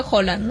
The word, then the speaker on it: Haaland: Bueno, aunque dicen que se pronuncia Haaland, ¿no? – Haaland.